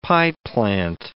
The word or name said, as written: pieplant